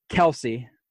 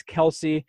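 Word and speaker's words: Kelce: But moving on, and sorry, Glenn, Kelsey. I always say Kels. I don't know. – I didn't know. I've never heard you say his name before, and I was like, did he just say Kels, like the book of Kels? – I don't know. I guess it's K-E-L-C-E.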